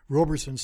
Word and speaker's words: Roberson: It looked like I thought he'd twisted his ankle or got his feet caught up, but losing Trey Robinson. Roberson, sorry. I pronounce it that way in my head.